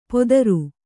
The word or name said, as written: ಪೊದರು